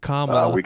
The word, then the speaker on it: Kamala: Kamala. It's like Camelot with a llama.